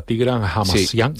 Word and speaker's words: Tigran Hamasyan: Nombrabas al pianista y compositor armenio, a Tigran Hamasyan, si lo he dicho bien.